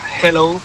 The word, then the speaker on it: hello: Y la segunda, dile a Elena que su dicción en castellano es la más perfecta que conozco, pero que cuando diga hello, que no pronuncie la J, que no es hello, que es yeah. Hello, que la aspire la J y no pronuncie la J potente castellana.